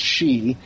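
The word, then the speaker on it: sídhe: And the Hill of Tara was always considered a place where the uh, the she I can't I can't spell it in Gaelic, but it's pronounced the she,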